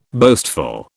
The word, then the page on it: boastful: British English